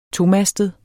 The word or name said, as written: tomastet